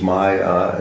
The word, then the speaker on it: maya: The word, see the word maya with two long A's, maya and then mayam, maya, maya.